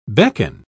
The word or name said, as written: beckon